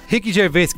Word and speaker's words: Gervais: Assim que se pronuncia? – Rick Gervais. – Grande fã.